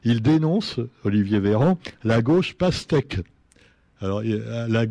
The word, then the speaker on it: Véran: Et là, c'est Olivier Véran, Véran pardon, pourquoi je me trompe toujours quand je prononce son nom, lui Oui, avant, il était ministre de la Santé.